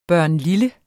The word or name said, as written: barnlille